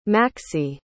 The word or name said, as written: Maxie